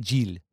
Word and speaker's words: Gilles: I think it's Gil, G-I-E, but it's pronounced Giel.